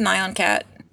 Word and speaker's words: Nyan: Why'd you send a picture of Nyan Cat? – Nyan. – Nylon cat. – Tell her how it's pronounced. Yes. – It's Nyan cat.